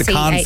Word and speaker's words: Cannes: They debuted it at the Cairns Film Festival that's going on at the moment over in Cairns. Not Cairns, K-A-C-A. Yeah, the Cannes Film Festival, the French one. – Is that how it's said, Cannes? – Yeah, I think so, yeah.